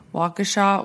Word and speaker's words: Waukesha: Waukesha, Wisconsin.